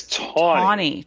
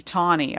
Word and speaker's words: Taney: How do you say his last name? Is it? – I believe it's Taney. – Taney. Okay. – Which is not phonetical at all.